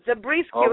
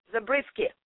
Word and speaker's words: Zabriskie: Just the way my name is spelled, and it's Zarina Zabriskie.